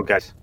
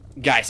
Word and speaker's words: Guice: I'm going to pronounce his last name wrong. Geis? Juice? I don't know. G-U-I-C-E. – Oh, Geis. – Geis, okay. Yeah, Dan Jurgens and Jackson Geis.